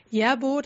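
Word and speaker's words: Järbo: Aber also da heißt es Yerbo tatsächlich und Svartafore. Das hat, Svartafore war ich mir schon ziemlich sicher, dass ich es richtig ausspreche, aber Yerbo war ich mir nicht so mit dem J. Das ist eher unüblich für Schweden und also tatsächlich wie im Deutschen betont quasi Yerbo-Garn. Genau.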